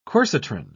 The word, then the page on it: quercitron: British English